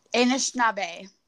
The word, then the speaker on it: Anishinaabe: Anishinaabe. – Anishinaabe.